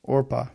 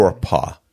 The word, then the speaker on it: Orpah: Orpah. – You know what? And I always say Oprah, because hearing Oprah's story, that's how she got her name, because her aunt pronounced it wrong.